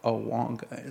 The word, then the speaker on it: Agwanga: It's spelled Agwanga, but it's pronounced Awanga.